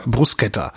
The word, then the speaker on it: Bruschetta: haben uns ne, zwei Vorspeisen, also so einen großen Vorspeisenteller mit so hier so Antipasti halt, dazu noch so Bruschetta, wie spricht man das eigentlich richtig aus? – Bruschetta, danke.